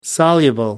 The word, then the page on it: soluble: American English